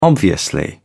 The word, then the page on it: obviously: British English